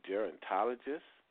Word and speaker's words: gerontologist: Ms. Hill is a certified wellness coach and a master level, and I hope I say this word right, gerontologist.